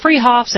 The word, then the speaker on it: Fruhoffs: Spelled Fruhoffs, pronounced Freehoffs at 33rd and Arapahoe in Boulder.